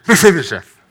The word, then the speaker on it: Mephibosheth: And I always feel the name Mephibosheth sounds a little bit like you're sneezing. So if you just want to kind of cover your mouth and go, Mephibosheth, I think that's the correct pronunciation, okay?